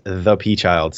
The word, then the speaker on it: ThaPchild: You can find me everywhere at ThaPchild, spelled with an A.